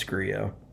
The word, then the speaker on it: Grillo: Emiliano Grillo, is it Grillo, Grillo? – It's Grillo. I think it's Grillo.